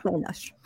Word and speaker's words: Tenoch: Sí, es Tenoch. – ¿Es, Tenoch? O sea, Tenochtitlán. – Todo, todo el mundo dice Tenoch Huerta.